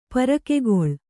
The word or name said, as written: ಪರಕೆಗೊಳ್